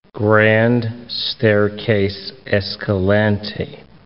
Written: Grand Staircase-Escalante National Monument

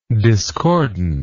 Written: discordantly

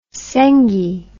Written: sengi

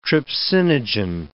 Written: trypsinogen

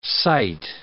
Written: sight

